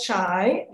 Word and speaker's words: Shai: Okay, so Not So Shy follows 12-year-old Shy. The spelling is S-H-A-I, but it's pronounced Shy.